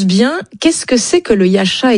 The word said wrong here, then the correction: Yatcha: exactement? – Oui, alors on prononce le Yatcha.